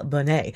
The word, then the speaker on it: Bonnet: And earlier, Quentin heard me mention that I was going to play you something from Samuel Bennett. And Quentin decided to email me and be like, just so you know, Samuel is French. It's actually pronounced Samuel Bonnet.